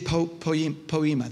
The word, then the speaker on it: poema: Said poema. That's how it's said.